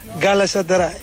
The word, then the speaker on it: Galatasaray: Olha, o Bruma é que diz Galatasaray na perfeição. Vamos ouvir. – Champion Galatasaray.